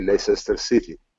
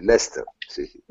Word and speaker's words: Leicester City: che è ovviamente Leicester City, anzi Leicester City per pronunciarlo in modo corretto.